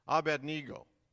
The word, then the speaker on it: Abednego: And Azariah he called, now get the way I'm going to pronounce this, Abednego. All right. Adam reminded me of that last week. That's how you pronounce that word.